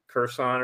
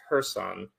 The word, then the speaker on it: Kherson: Kherson or something like that. Though I hear it's pronounced Kherson.